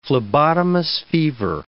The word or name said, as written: phlebotomus fever